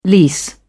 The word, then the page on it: lease: British English